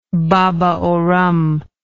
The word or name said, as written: baba au rhum